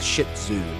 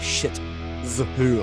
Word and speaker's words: shih tzu: You have to adjust the pronunciation. Like, say, it was a shih tzu, not a shit zoo.